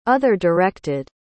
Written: other-directed

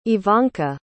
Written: ivanka

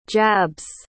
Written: jabs

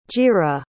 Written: jira

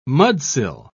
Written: mudsill

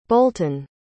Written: bolton

